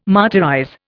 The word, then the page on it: martyrize: Indian